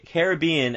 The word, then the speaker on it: Caribbean: So my pick of this week is Pirates Caribbean, Caribbean, as you're supposed to pronounce it, Pirates Caribbean, Curse of the Black Pearl.